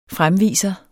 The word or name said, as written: fremviser